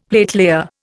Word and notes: platelayer: Indian

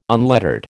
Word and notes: unlettered: American English

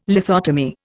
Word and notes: lithotomy: Indian

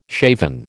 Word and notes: shaven: American English

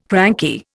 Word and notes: cranky: Indian

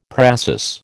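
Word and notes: précis: American English

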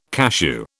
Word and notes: cachou: British English

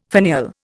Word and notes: finial: Indian

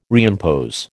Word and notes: reimpose: American English

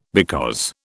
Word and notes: because: British English

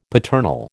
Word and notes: paternal: American English

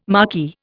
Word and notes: marquee: Indian

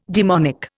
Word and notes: daemonic: Indian

